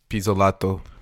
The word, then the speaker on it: Pizzolatto: How do you say this name? You're Italian. – Pizzolatto. – Oh. That's what I was going to say, too.